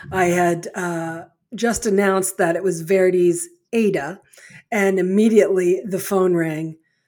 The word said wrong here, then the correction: Aida: I answered it and he said, "it's pronounced Aida. Please make note of it.